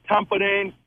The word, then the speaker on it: Tampere: I'm probably going to butcher this, but is it Pierinto Tampere? – It's the Tampere Pierinto.